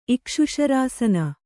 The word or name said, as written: ಇಕ್ಷುಶರಾಸನ